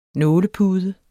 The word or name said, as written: nålepude